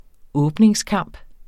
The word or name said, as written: åbningskamp